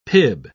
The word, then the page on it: pib: British English